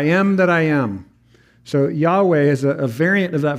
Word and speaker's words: Yahweh: So Lord, just to remind you, when it's all capital letters, L-O-R-D, the ancient pronunciation of this is typically Jehovah. You might have heard that. Or Yahweh is the more modern way of pronouncing this.